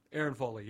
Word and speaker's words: Erin Foley: Am I saying it right, Erin Foley? – Erin Foley, yeah.